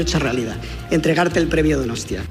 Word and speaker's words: Donostia: Donostia. Okay, yeah. – Pues, es que ricasco, Donostia, es que ricasco.